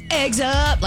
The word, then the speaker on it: Eggs Up: week to Eggs Up in Germantown. – You're saying it wrong. – Am I? – It's Eggs Up, like Cameo. Like Word Up, but Eggs Up.